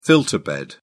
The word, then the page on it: filter bed: British English